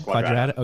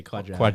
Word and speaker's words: Quadrata: I've heard Quadrata. – Yes, Quadrata. – Okay, Quadrata.